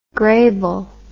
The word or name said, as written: grable